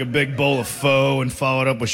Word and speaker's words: pho: It's spelled P-H-O and people are like, it's pronounced pho, and they get all upset.